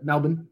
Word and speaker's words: Melbourne: I'm not how to say it, but Melbourne.